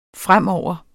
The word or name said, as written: fremover